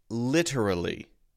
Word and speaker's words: literally: So the pronunciation is literally. Four-syllable word. All right. Now, a lot of people will say this with the light D sound instead of the T sound.